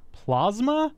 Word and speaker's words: plasma: Plasma? – Plasma, like Mazda. Listen for it. It's the strangest thing. And we both looked, and it's definitely pronounced plasma from what we could determine.